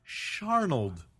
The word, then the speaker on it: Searnold: S E A R N O L D, and then he spelled it out phonetically for me. Sharnold.